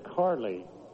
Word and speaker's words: Charlie: Carly Scott, we mentioned that it's in the program as Carly. He pronounces it Carly. But as far as the spelling, it's spelled Charlie.